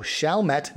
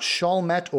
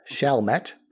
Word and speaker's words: Chalmette: Also Chalmette. I think I got that one a little wrong too. Chalmette, not Chalmette or Chalmette, but Chalmette.